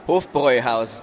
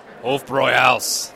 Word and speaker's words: Hofbräuhaus: I say it like an English guy, though, right? Hofbräu. Okay, wait. – Hofbräuhaus. – Hofbräuhaus.